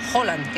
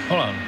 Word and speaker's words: Haaland: que él quiere que le llamemos Haaland, que esa es otra. – Haaland. – Sí, lo dijo en la pronunciación de la FIFA, dijo, my name is Haaland, y dijo, vale, ok.